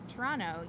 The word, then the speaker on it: Toronto: There was a bingo board where you had to find particular people that filled qualifications like can name three Toronto politicians or know how to pronounce it, which apparently is Toronto. You don't say the second T.